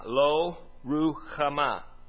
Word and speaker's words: Lo-Ruhamah: Easiest way to do it is just start at the end and work your way backwards. Ma, ha, ma, Ruhamah, Lo-Ruhamah.